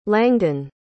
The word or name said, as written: langdon